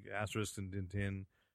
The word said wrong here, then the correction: Tintin: You're talking about Tintin? – Yes.